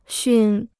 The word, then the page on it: 熏: xūn